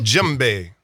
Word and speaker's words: djembe: D-J-E-M-B-E. – That's the name of the instrument? – That's a djembe. – Wrote a song about it. Want to hear it? Here it goes. – Did you know that? – No. – Okay. – The D is silent.